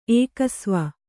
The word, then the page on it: ಏಕಸ್ವ: kannada